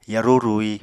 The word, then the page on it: yarú rui: apatani